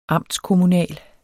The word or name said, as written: amtskommunal